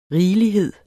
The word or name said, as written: rigelighed